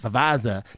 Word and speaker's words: Favazza: Fazaza. That's a I'm going to have to pronounce this right. Unpronounceable names are so hard. Vincent J. Favaza, F-A-V-A-Z-Z-A. How do you say that? Favaza. That's it, Favaza, I guess.